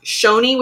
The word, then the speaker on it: Choni: Then we have Choni, which we learned is actually pronounced Shoni, which makes sense.